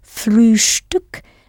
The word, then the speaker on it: Frühstück: Especially if you remember to pronounce the ST in Frühstück as though it has a sh before the T. Frühstück.